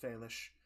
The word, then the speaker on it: Félix: It's spelled João Félix, but it's pronounced Félix.